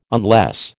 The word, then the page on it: unless: American English